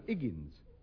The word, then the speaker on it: Higgins: H, hi, egg, egg, hi, hen. – Higgins. – No, Higgins. No, the H is silent as in orse.